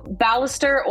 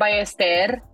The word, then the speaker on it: Ballester: So Ballester or Ballester, depending how crazy you're feeling.